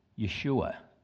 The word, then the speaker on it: Yeshua: And it was Moses that changed his name to Joshua, which properly pronounced is Yeshua.